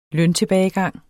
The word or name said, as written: løntilbagegang